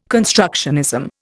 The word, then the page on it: constructionism: Indian